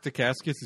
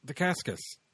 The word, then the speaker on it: Dacascos: It ends with an S. Dacascos. I've been pronouncing it that way for decades.